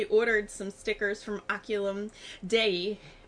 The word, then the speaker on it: Oculum Dei: At least my Latin teacher would have said it's Oculum Dei.